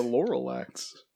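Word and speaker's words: Lorax: This comes to us from the director, Chris Renard, who did Despicable Me 1 and 2, as well as The Lorelats. Lorelats? – What did you say? The Lorelax? – Lorelats. – Well, you had a whole extra syllable in there.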